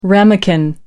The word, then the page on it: ramekin: American English